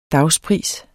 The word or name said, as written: dagspris